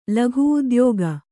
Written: ಲಘುಉದ್ಯೋಗ

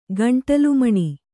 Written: ಗಂಟಲುಮಣಿ

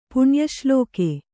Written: ಪುಣ್ಯಶ್ಲೋಕ

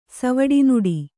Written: ಸವಡಿನುಡಿ

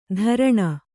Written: ಧರಣ

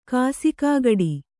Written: ಕಾಸಿಕಾಗಡಿ

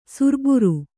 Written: ಸರಬರ

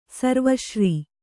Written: ಸರ್ವಶ್ರೀ

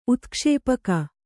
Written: ಉತ್ಕ್ಷೇಪಕ

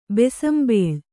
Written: ಬೀಸುಂಬಲೆ

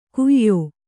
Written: ಕುಯ್ಯೋ

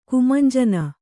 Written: ಕುಮಂಜನ

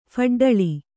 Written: ಫಡ್ಡಳಿ

